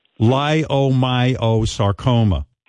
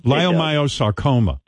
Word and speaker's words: leiomyosarcoma: Unfortunately, Syke is suffering from, I hope I get this right, stage 4 leiomyosarcoma. – That is correct, sir. – Leiomyo? – Yes. Leiomyo. Sounds kind of like an Italian dish. – Now, how do you say it? – I know. It sounds like the lyric of a song. – Leiomyosarcoma. – Leiomyosarcoma is correct.